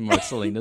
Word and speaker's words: Marceline: We looked at a YouTube video on how to pronounce it, and Marceline is what came up.